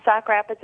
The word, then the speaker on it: Sauk Rapids: Sauk Rapids, Minnesota. Sauk Rapids, yes. – Am I saying that correctly? – Yep, Sauk Rapids is correct.